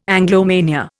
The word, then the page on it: Anglomania: Indian